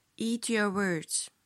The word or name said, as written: eat your words